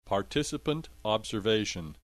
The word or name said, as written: participant observation